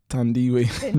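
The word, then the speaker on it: Thandiwe: And, of course, I'm talking about Tandy Newton. – Who? You better say her name correctly. – Tandy Newton. Tandiwe Newton.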